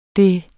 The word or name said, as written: det